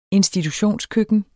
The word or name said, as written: institutionskøkken